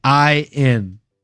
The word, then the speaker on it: Szczecin: He's fighting out of Szczecin. I think I said that right. Szczecin, Poland. – You did way better than I would have done. – It's for those of you in the chat, it's Szczecin, Poland.